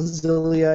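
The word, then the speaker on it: Xillia: Tales of Exilia, Zilia, I believe it's actually pronounced, uh, 12 on the 3.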